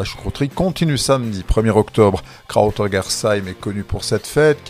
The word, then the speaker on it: Krautergersheim: Mais ce n'est pas mieux en alsacien, Kruterische, on entend également Kruterjersche.